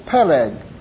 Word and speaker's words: Peleg: The name of one was Peleg, and that is the Hebrew pronunciation. That's how it's pronounced.